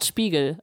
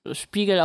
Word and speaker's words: Spiegel: Genau, Scott Spiegel. Also Spiegel auf Englisch ausgesprochen.